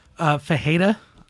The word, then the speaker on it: Ferreira: Cesar Ferreira, is that how you say his name? Cesar Ferreira? – Fajeda.